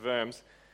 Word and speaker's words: Worms: It sounds like Diet of Worms, but it's called the Diet, which is a church council, and Worms, which is pronounced Worms,